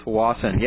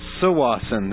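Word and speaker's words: Tsawwassen: It's actually pronounced Sawasin. There, that's even harder. – It starts with a T, yeah. – Sawassen.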